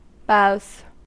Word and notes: boughs: American English

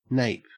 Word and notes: nape: Australian English

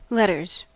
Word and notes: letters: American English

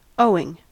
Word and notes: owing: American English

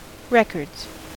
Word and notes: records: American English

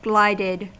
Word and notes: glided: American English

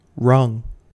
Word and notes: rung: American English

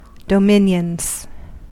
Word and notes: dominions: American English

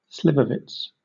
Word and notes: slivovitz: British English

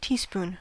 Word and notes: teaspoon: American English

